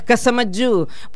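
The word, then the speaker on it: Casamayou: Muy bien, te lo voy a decir de varias formas. Matías Casamayú, porque tiene algo de vasco francés, me dijo. Algo, ¿no? La esencia, ¿no? Entonces se escribe How.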